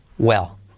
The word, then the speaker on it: well: Very, semiclave V, clave R, la U que viene detrás de la R, very well. Y esa semiclave W que no es gu, el, sino U, L. Y la L que casi no se pronuncia, well, well.